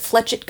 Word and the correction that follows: flechette: guns. – Flechette.